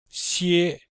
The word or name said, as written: xie1